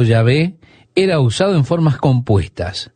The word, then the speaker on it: Yahvé: Hay quienes dicen que se pronunciaba Jehová, pero hay quienes dicen que se pronunciaba Yahvé. Todo lo que tenemos originalmente, estimado oyente, son consonantes. I, H, YHWH. Reitero, YHWH. Quedaron fueras las vocales, así que no sabemos cuál era la pronunciación correspondiente. Pero muchos eruditos piensan que era Yahvé.